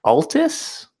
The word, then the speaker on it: Altis: a piece of software called Altis, A-L-T-I-S. I'm going to pronounce it Altis, as in like alternative is, A-L-T-I-S.